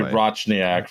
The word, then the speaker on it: Rochniak: Yeah, it should be Rochniak, from what I understand. – Rochniak. – I blame Ellis Island. Anyway. I was also aware of that, but this is how the family name has been pronounced as long as I have been alive.